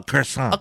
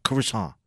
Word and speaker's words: croissant: Well, listen, what I'm getting at, the point I'm trying to make is you can't eat a croissant in your car, or as the French like to call it, a croissant. – A croissant. – They don't pronounce the T. It's just croissant.